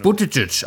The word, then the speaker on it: Buttigieg: Uh, Buttigieg, aber nennen Sie mich doch einfach Bürgermeister Pete.